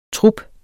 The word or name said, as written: TRUPPER